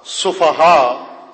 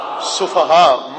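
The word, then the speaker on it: سفہاء: دوبارہ ہجے کرتا ہوں, توجہ فرمائیے. سین فے ہے, دو آنکھوں والی ہے, اور الف ساتھ اس کے حمزہ. کیا بنتا ہے تلفظ اس کا؟ سفہا. سب بولیں تاکہ میں مطمئن ہو جاؤں. سفہا.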